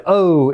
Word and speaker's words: owe: The word O is a strange one. It's spelled O W E, but it's just pronounced like the letter O.